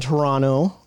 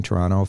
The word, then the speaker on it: Toronto: And you two were just in Toronto, right? – We were. – The second tea is silent.